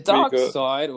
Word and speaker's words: Darkseid: Wasn't that long, but we saw a glimpse of Darkseid in it. That was pretty cool. What else did we get? – Is it Darkseid or is it Darks?